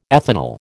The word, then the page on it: ethanal: American English